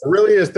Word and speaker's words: Aurelius: So first I found the book online, Meditations, and I don't even know how to say this cat's name, but Marcus Aurelius. – Aurelius, thank you.